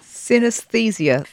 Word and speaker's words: synesthesia: But she also has, here we go, I'm going to try and pronounce this, synesthesia. Am I pronouncing that correctly? – Synesthesia, that was very good attempt. – Right, okay. – Just the extra S in the middle.